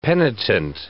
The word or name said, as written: penitent